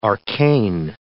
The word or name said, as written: arcane